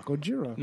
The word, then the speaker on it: Gojira: Gojira. – No, I think you said Godzilla. – No, Gojira. – The band? – No, Gojira is how you say Godzilla in Japanese.